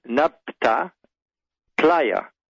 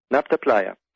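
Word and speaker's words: Nabta Playa: It's N-A-B-T-A, Napta, Playa, P-L-A-Y-A, Napta Playa.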